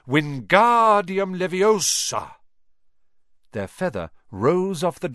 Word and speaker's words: Wingardium Leviosa: "Wingardium Leviosa!" – he shouted, waving his long arms like a windmill. – "You're saying it wrong," Harry heard Hermione snap. "It's Wingardium Leviosa. Make the gar nice and long."